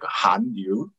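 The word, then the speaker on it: Hallyu (한류): So yeah, the pronunciation is like Hallyu.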